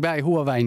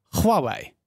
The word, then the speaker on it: Huawei: Huawei is Chinees. Huawei is Engels en Huawei zeggen we in Nederland vaak. Maar ik zeg altijd Huawei.